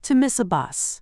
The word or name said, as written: to miss a bus